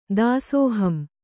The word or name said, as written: ದಾಸೋಹಂ